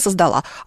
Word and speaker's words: создал: Она создала. А вот если он создал. По аналогии, например, со словом «отдал», потому что часто люди говорят «отдал», на самом деле нет. Он отдал, и даже если их много, то они отдали.